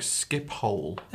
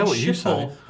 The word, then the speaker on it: Schiphol: So he says it's actually pronounced more like Skiphole. – Yeah, it's Skiphole Airport. I said this about 3 weeks ago, and then you're like Schiphol, and then you keep on calling it Schiphol.